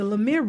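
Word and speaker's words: Lumière: On February 13th, 1895, I'm going to try to say this right, the Lemire brothers patent their cinematograph. – Try saying that one three times fast. – I know. It's cinematograph. Now, the Lumiere brothers in 1895 patented their cinematograph, one of their earliest motion picture projectors of all time.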